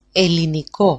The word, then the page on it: eliniko: eliniko-